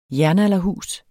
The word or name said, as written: jernalderhus